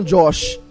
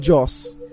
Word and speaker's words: Joss: Por que, que vocês falam Josh se é Joss? Não sei. Não sei. Agora pode seguir, pode pensar, seguir. Mas, mas, a é pronúncia é, né, é, Josh? É não é Josh. – Não? Não. É tipo Joss Stone, sacou?